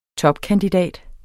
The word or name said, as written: TOPKANDIDAT